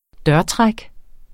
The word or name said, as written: dørtræk